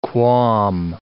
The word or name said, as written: qualm